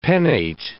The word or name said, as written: pennate